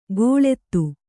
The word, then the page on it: ಗೋಳೆತ್ತು: gōḷettu